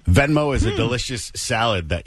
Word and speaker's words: Venmo: Venmo. – There we go. Hold on one more time. – Venmo.